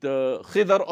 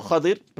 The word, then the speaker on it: Khidr: When Sayyidina Musa alayhi salatu was salam embarked on this journey of his to meet Khidr or Khadir, pronounced both ways, at the confluence of the two oceans, and with him he took his servant.